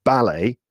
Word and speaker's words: ballet: The first group is in America, these words would probably be pronounced with the stress on the last syllable. – Okay. – But in the UK, they are pronounced with the stress on the first syllable. Okay. So I'll say them with British English and then maybe with American English. So we in the UK, you would go to the theater to see a ballet.